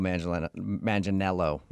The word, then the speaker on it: Manganiello: Because we talked to him, and he told us that a lot of people say he looks like Joe Manganiello, right? That's how you say it? Yeah. – Yeah. You got it. You nailed it.